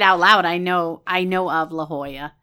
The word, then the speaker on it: La Jolla: She goes, it's pronounced La Jolla, which we never would have gotten.